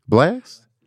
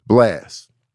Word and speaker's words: BLXST: The other cast in the running, uh, Brent Faiyaz, Burna Boy, Drake, The Weeknd, and uh, how do you say this name? B L X S T, Blast, Blast.